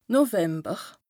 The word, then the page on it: November: Bas-Rhin; Strasbourg